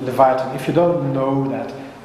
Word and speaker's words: Leviathan: Leviathan, yeah.